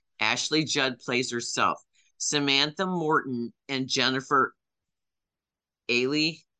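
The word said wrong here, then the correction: Ehle: I'm not sure how to pronounce her last name. A-H-L-E. They both play past Weinstein employees in the UK. And this is about... – It's Ely.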